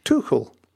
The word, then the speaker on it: Tuchel: and it is Thomas Tuchel. Mm. You're absolutely right. It's slightly on the CH.